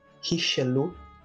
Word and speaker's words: Richelieu: Nossa querida Richelieu, é assim que se pronuncia, até onde meu conhecimento vai.